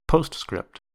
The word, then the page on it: postscript: American English